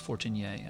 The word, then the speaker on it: Fortener: My French pronunciation of my name is Fortener, actually.